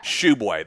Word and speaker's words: Tshiebwe: But if you go on West Virginia's actual player profile for him, they specify Tshiebwe. They have it spelled out for him. Regardless, I saw that today, too. – Yeah. – I was like, wow, I've been saying this wrong for 2 years now.